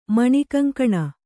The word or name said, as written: ಮಾಣಿಕಂಗಾಣ್